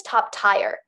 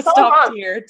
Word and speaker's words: top tier: I'm like, I thought it was top tire. – No, so it's top tier.